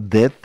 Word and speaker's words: Dehd: Se llama Dead, se pronuncia como muerto aunque se escribe diferente, D-E-H-D.